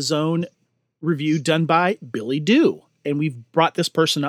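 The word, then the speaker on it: Doux: She has her own website, Dew Reviews, and that's D-O-U-X. But it's pronounced Dew.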